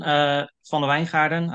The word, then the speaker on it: van den Wijngaarden: My name is Jonathan van der Wijngaarden. I'm not trying to going to try to pronounce that in English because that's usually going to sound pretty weird.